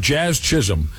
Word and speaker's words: Jazz Chisholm: If you can get a hold of this video, I've never seen such a wild contrast between the way Chaz, how do you say his name again? Jazz Chisholm.